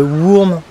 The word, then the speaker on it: Wurm: donc je le prononce très mal mais parce que c'est autrichien, voilà, mais normalement ça se dirait Wurm, enfin ça s'écrit W-U-R-M, ça veut dire ver en allemand mais ver de terre quoi.